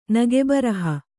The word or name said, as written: ನಗೆಬರಹ